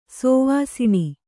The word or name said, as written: ಶವಾಸನ